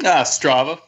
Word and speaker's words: Strava: He's a fan of, I believe it's Strata. Is that right? Strata? Am I saying that correctly? – Strava.